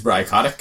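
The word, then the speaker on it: Bry Kotyk: My name is Bri Kotick.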